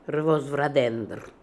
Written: Revozvradendr

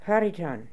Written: Hariton

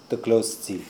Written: Dglozdzi

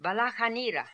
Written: Balakhanira

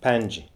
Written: Pianje

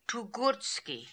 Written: Toogoortski

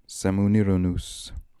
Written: Semooniranoos

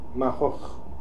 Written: Makhokh

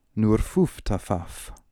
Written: Noorfooftafaf

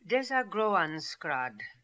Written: Desagroanskrad

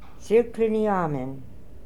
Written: Sirkliniamen

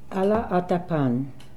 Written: Alla attapan

